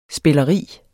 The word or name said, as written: spilleri